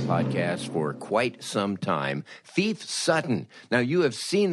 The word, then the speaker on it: Phoef: Phoef Sutton, and of course, he pronounces his name Phoef and spells it P-H-O-E-F.